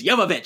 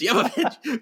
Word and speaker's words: Jovovich: And I remember this because I listened to the Resident Evil movie commentary, and she's like, "It's Jovovich." She kept saying it. She kept correcting people. It's Jovovich, because even her castmates kept saying it wrong.